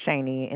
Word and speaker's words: Shaney: My name is Shaney, and it's spelled S-H-A-N-E-Y.